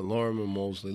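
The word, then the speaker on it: Lorimer Moseley: The well-known, I always pronounce his name wrong, Lamar Mosley. – Lorimer Mosley. Let's call him L. – L.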